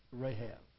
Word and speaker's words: Rahab: And Salmon begat Boaz, that's Boaz of the Old Testament, of, it's pronounced here, Rachab, it's Rahab.